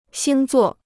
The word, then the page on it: 星座: xīng zuò